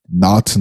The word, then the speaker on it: Knot: Knot, no caso, é K-N-O-T, tá, gente? Nó. De em, nó. Em inglês. É o K é mudo.